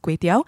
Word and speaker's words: kway teow: So I'm going to start off by saying that I wasn't aware of the confusion, mostly because regardless of how you spell Kway Teow, it is pronounced Kway Teow, and and it tastes the same, more importantly.